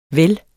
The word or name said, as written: vel